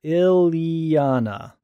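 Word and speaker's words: Illyana: Brian Miller answers the long-awaited answer to my question of how do you pronounce Colossus's little sister's name? And he says that the name is pronounced Ileana, right? – Yep.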